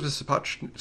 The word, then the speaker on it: Sapochnik: In terms of Sapochnik, is that how you pronounce his name? – Yep.